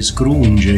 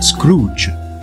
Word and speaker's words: Scrooge: C'è, cioè, chi dice Scrooge, chi scrunge, chi Scrooge. – Scrooge. – Credo Oriana mi dirà ok, si dica Scrooge.